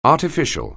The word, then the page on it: artificial: anglais